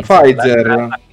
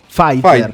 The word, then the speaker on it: Pfizer: Parliamo invece della Psizer Italia, ma qualcuno sa pronunciarlo correttamente questa, questa casa farmaceutica? – Mm, Pfizer. Che muta come Django. Pfizer.